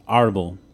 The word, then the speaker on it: Árbol: Like, well, it potentially is because the accent is on the first syllable. Árbol.